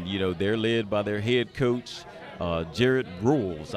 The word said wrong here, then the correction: Rolfes: I think that's how you say his name. – Is it Rolfes? – Okay, Jared Rolfes.